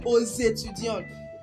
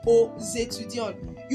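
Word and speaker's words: aux étudiants: You will notice a liaison in the Ozetudion as I pronounce the X as Z. I pronounce letter X as Z because the word that follows it is a vowel.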